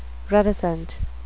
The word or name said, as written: reticent